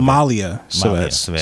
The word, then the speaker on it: Malia Civetz: Malika Savetz. No, Savetz. Malia Savetz. Sorry. Okay. M-A-L-I-A-C-I-V-E-T-Z.